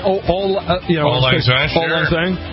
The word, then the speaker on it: Auld Lang Syne: Auld Lang Syne. – Yeah, it's spelled A-U-L-D, but it old, Auld Lang Syne. Everybody says old.